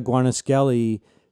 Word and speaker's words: Guarnaschelli: A lot of people don't remember that right around that time, Maria Guarnaschelli, which is how she pronounces her last name, Pub was the editor of The New Joy of Cooking.